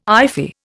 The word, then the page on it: iffy: Indian